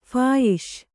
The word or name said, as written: ಫಾಯಿಷ್